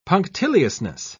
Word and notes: punctiliousness: British English

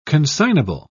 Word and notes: consignable: British English

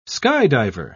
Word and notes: skydiver: British English

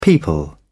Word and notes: people: British English

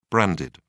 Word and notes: branded: British English

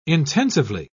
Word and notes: intensively: British English

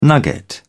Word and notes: nugget: British English

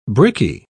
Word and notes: brickie: British English

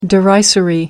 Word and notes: derisory: American English

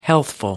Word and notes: healthful: American English

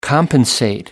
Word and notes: compensate: American English

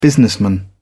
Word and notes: businessman: British English

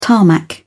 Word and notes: tarmac: British English

